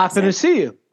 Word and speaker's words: Athanasiou: Athanasiou.